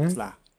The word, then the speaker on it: Cotillard: Marion Cotillard. – Yeah, Marion Cotillard, Cotillard, né? Cotillard. Tá ruim hoje, Davi.